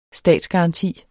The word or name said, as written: statsgaranti